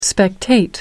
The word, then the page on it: spectate: American English